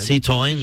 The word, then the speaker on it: Citroën: Citroën. – Sí, muy bien. – Muy bien. – Yo creo que mejor incluso Alex de Llano. – Très bien. – Chicos, un dato relevante. – Citroën.